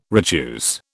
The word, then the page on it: retuse: British English